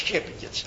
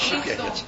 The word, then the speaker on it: Tsjepjenits: Prof Russisch, maar ik sprak dat altijd als Tsjepjenits. En dan had ik één keer een Russische student erbij die zei, u spreekt het verkeerd uit. Oh, sorry. Ja, als Tsjepjenits. Klemtoon valt verkeerd.